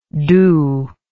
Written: Doubs

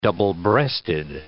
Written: double-breasted

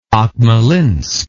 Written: Akmolinsk

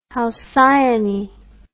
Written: halcyone

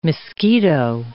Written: Miskito

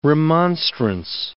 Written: remonstrance

